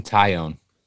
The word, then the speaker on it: Taillon: and Jameson Taillon, I believe is how you say his name. – Taillon, yeah.